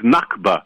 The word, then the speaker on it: Nakba: One is Naksa, N-A-K-S-A, and the other one, which you just pronounced correctly, is Nakba, N-A-K-B-A.